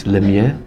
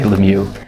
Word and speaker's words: Lemieux: Yeah, so technically in French it's Lemieux, but of course when my family migrated from Canada, they Americanized it. So we say Lemieux, but the French will be horrified.